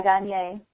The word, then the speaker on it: Gagné: And Steve Gagné, G-A-G-N-E, is that how you say it? Is it Gagné? I don't know. – Yeah, Gagné. Steve Gagné.